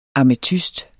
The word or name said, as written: ametyst